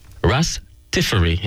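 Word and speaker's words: Ras Tafari: So ras Tiferi is how it's usually pronounced. Okay. Rastafari. When you say ras, that's a Jamaican pronunciation. That's a Jamaican thing.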